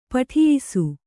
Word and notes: ಪಠಿಯಿಸು: kannada

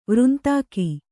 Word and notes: ವೃಂತಾಕ: Kannada

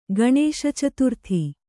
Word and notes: ಗಣೇಶಚತುರ್ಥಿ: gaṇēśacaturthi

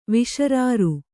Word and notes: ವಿಶರಾರು: Kannada

